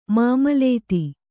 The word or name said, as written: ಮಾಮಲತಿ